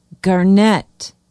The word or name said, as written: Garnet